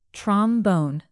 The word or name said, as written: trombone